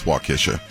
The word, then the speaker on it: Waukesha: Yeah, it's Waukesha.